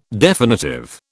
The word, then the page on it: definitive: British English